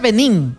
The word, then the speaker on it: Benín: No, se pronuncia Benín. – Benín se pronuncia. – Benín. – Benín, porque es con tilde.